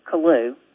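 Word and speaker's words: Killough: I grew up on a farm in rural Arkansas, and my family pronounces the name Kalu, like K-I-L-L-L-O-U. But in Ireland, the name is pronounced Killach, which means church by the lake. The L-O-G-H at the end of the name is a variation of the word loch, as in Loch Ness.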